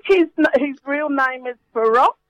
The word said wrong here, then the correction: Farrokh: Farrokh Balsara.